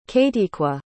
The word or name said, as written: kayteequa